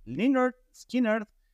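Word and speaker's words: Lynyrd Skynyrd: Y pues primero de los clásicos, no podría ser de otra manera, Liner Skinner, quien no sabrían en algún momento cómo se pronunciaba, pues es así, Liner Skinner con su clásico Sweet Home Alabama.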